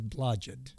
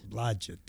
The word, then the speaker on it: Blodgett: My last name is Blodgett. – Blodgett. – Blodgett, yes.